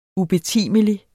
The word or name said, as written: ubetimelig